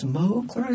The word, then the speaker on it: Smokler: No, they usually say, like when I ask that question, they usually say Smokler. I say, "Yeah, that's right."